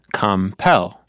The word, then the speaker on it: compel: Oh, two syllables, and the second one has the stress, compel.